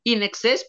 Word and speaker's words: INXS: Y pues para dar ahí uno que otro datillo curioso, In Excess, pues bueno, eh, surge con esta pronunciación debido a que ellos se querían llamar eh, In Excess, como tal que es en exceso, ¿no? pero aquí es INXS, ¿no? Y al momento de pronunciarlo en inglés, pues se suena como, como si estuviéramos diciendo in excess, ¿no?